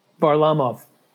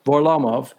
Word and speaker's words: Varlamov: Semyon Varlamov? – Varlamov. – Varlamov.